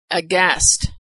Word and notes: aghast: g

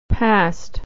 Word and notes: passed: American English

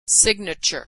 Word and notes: signature: American English

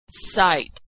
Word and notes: sight: American English

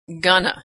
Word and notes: gonna: American English

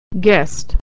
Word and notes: guest: American English

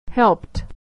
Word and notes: helped: American English

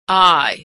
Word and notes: I: American English

